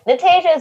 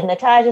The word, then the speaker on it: Natajia: Is it Natajia or Natajia? What do you prefer? – Natajia is good. Natajia is also good.